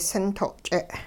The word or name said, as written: sinto'-ch'e